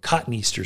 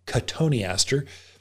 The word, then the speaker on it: cotoneaster: But they don't bother cotoneaster, or cottonaster is kind of how it's spelled, but cotoneaster.